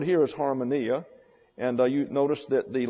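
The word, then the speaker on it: harmonia (ἁρμονία): Therefore, you have harmonia, accent on the next to the last syllable.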